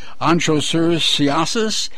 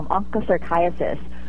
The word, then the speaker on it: Onchocerciasis: and I'm probably not pronouncing it correctly, Onchocerciasis, also known as river blindness. Is that right? – Yeah, that's right. It is a tough one to pronounce, Onchocerciasis.